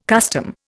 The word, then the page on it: custom: Indian